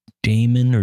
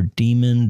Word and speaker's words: daemon: Power D is the power daemon or demon, depending on how you want to pronounce it, that the macOS uses to handle power events.